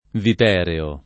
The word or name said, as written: vipereo